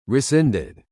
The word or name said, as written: rescinded